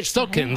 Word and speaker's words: Spokane: Desde Spokane, dices.